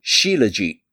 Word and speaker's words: Shilajit: But recently, I would say in the last 10 years, I've started taking Shilajit, S-H-I-L-A-J-I-T, Shilajit. It's how it's pronounced.